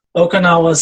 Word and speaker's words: Oconahua: The best example people could probably find is the Palacio de Acomo in Okinawa in Jalisco. It sounds like the Japanese island, but it's spelled differently. It's Okinawa spelled O-C-O-N-A-H-U-A.